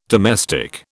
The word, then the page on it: domestic: British English